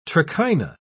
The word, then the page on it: trichina: British English